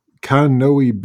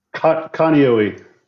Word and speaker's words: Kaneohe: Your moves with that job have been to Whidbey Island, to Fort Meade, Maryland, to Kaneohe Bay. I don't know if I pronounced that right. I've never been there. – Kaneohe. – Kaneohe. All right.